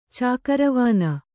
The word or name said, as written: ಚಾಕರವಾನ